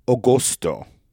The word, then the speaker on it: agosto: And in Italian, it's pronounced agosto. Agosto. Agosto. Agosto. It's spelled A-G-O-S-T-O. Agosto. Agosto. A-G-O-S-T-O.